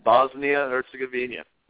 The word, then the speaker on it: Bosnia-Herzegovina: We have Argentina, Iran, Nigeria, and Bosnia-Herzegovina.